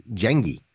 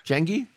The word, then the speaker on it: Jengi: It's easy, man. It's Swedish for gang. So it translates to gang. It's Jengi. – Jengi? J-E-N-G-I. Yeah.